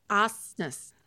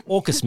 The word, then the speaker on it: AUKUS: On how to pronounce it. – AUKUS. – AUKUS. – Yeah. – We are now all members of AUKUS. – AUKUS, mate. It's just AUKUS.